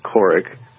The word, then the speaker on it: Kourik: Okay, my website is just my name without a space, so it's Robert Couric. Sounds like Katie Couric, but she spells her name wrong. Mine's spelled K-O-U-R-I-K.